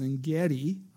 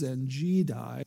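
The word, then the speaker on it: En Gedi: Some people pronounce it Engedi. I think the right pronunciation is Engedi.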